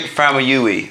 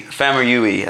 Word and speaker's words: Famuyiwa: I pronounce it Rick Famuyiwa. Famuyiwa. I think I'm pronouncing it right.